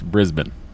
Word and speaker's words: Brisbane: Andy, if you're going to do the Australian accent, it's Brisbane. – Brisbane. – Yeah, here you go. – Is it? – Is it? – Do I really deserve... – That's how they say it.